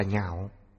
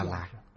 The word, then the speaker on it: nhạo: Chữ này đọc là nhạo, chứ không đọc là lạc.